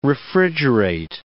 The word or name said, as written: refrigerate